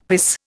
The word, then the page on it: piss: Indian